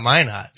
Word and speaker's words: Minot: Well, somebody gave us a class on Minot and what's there. – I'm so happy you know how to say it, pronounce that. – Yeah, well, it's Minot.